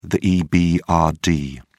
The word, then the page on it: ebrd: British English